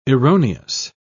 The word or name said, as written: erroneous